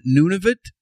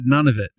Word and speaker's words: Nunavut: Now you pronounce it Nunavut. – Yeah. – I always thought it said none of it.